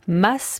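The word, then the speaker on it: Mads: Bonjour Charline, comment prononcez-vous le nom de notre invité ? – Mats Mikkelsen. – Pas Mats. – Non, parce que le D est silencieux. – Donc on dit Mats.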